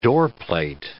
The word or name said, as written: doorplate